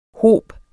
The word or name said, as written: hob